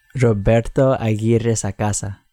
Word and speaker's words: Roberto Aguirre Sacasa: Roberto Aguirre Sacasa.